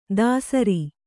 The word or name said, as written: ಧಸರಿ